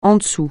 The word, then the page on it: en dessous: français